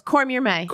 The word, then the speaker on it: Cormier-May: No, you're close. Cormier-May.